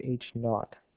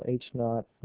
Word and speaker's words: H naught: It'll be written as H sub 0 or H naught is the way it's pronounced. The subscript 0 is pronounced naught, so H naught, null hypothesis.